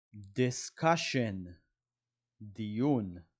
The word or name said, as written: discussion